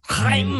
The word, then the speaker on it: Haim: Is it Haim or Haim? – Haim. – That's what I thought.